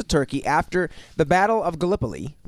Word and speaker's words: Gallipoli: Gallipoli.